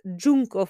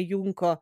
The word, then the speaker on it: Junko: Tako, da, tako tudi nisem izgovarjala, ampak Džunko Furuta, ne Junko, sorry, Džunko, ups zmotila sem se.